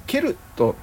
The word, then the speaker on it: ケルト: CELTIC ですかセルティックねこれがねケルトって読む